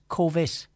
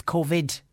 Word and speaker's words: COVID: It's not COVID, it's COVID. There's a D on the end, not a T.